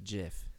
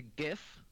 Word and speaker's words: GIF: Jonah Hill GIF. Is that how you say it? Is it GIF or is it JIF? Somebody? Anybody? – I think it's a GIF.